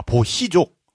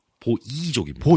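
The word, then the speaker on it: Boii: 아, 보희족. – 보이족입니다.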